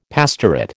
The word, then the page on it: pastorate: American English